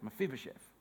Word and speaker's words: Mephibosheth: How do you say that? Mephibosheth.